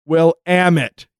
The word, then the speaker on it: Willamette: The river running through Pertland is pronounced Will Amit, you dumb shit.